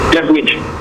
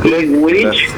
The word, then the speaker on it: Ledwith: Ledwith. – Pero más lento, por favor, Aaron. – Left, como de izquierda. – Sí. – Ledwith.